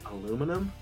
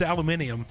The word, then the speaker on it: aluminium: Aluminum? – Aluminum, right? We're all wrong. – Yeah, no, it's aluminium. – Aluminium. Yeah. – That is the correct pronunciation.